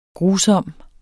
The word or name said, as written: GRUSOMSTE